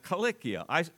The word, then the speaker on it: Cilicia: And then up at the top, you take a little circle out of Syria and you have Cilicia. I pronounce it that way because in Greek, there are two kappas or two Ks, and in English we usually pronounce those hard sounds, Cilicia, but it doesn't matter.